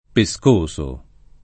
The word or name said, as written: pescoso